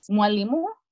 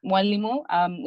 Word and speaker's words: Mwalimu: It's Mwalimu. Mwalimu, which means teacher.